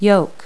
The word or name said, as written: yoke